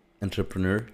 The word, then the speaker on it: entrepreneur: Entrepreneur.